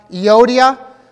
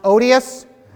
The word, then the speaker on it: Euodia: We have Euodia, sometimes intentionally mispronounced Odious, and then you have Syntyche, sometimes intentionally mispronounced, so touchy.